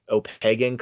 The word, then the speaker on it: Opequon: It's pronounced Opegan Collective. It's spelled OPEQUON period collective.